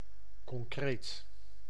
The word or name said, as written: real